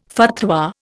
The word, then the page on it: fatwa: Indian